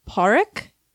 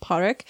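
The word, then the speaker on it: Pádraic: One is played by Colin Farrell, who plays, excuse my Irish pronunciations, it's spelled P-A-D-R-A-I-C, but it seems to be pronounced Parik. So that's my best way of saying it. I'm sorry if that's incorrect, but it's like Parik.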